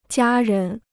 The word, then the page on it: 家人: jiā rén